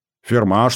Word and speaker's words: фирма: Ферма, чтоб?» Это заветное слово произносили с ударением на последний слог.